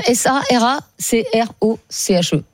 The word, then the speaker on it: Sarah Croche: Donc, Sarah Croche, Sarah Croche. – Oui, comme ça se prononce.